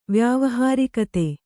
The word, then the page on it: ವ್ಯಾವಹಾರಿಕತೆ: vyāvahārikate